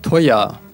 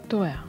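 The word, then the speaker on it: teuer: Тоя. – Тоя.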